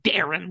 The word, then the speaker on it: Deron: It is Deron, The way it's spelled, it's not Deron. – Darren Williams.